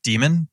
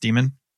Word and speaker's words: Daemon: I guess if I have to give a specific book recommendation, I would say start with Daemon, Daemon. Right? So I always kind of pronounce it daemon too, but I think it's supposed to be just pronounced demon.